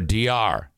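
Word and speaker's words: Diar: This is his daughter, D.R. I think that's how you say it. D-I-A-R. D.R.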